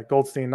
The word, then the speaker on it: Goldstein: Do you say Goldstein? Is that how you pronounce it? – Yeah, Goldstein, not Glitchstein, like my parents used to say.